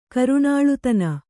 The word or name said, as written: ಕರುಣಾಳುತನ